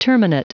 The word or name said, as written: terminate